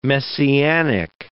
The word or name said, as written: messianic